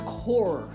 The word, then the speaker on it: corps: Kernel, C-O-L-U-O-N-E-L. Excuse me. Kernel, C-O-L-O-N-E-L. Another one was core, C-O-R-P-S, right?